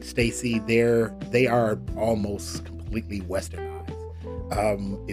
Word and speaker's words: STAYC: Number two, there's a group, a very young group called Stacy, S-T-A-Y-C. It's pronounced Stacy.